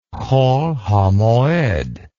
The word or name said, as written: hol hamoed